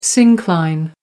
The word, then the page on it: syncline: American English